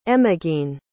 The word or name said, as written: emogene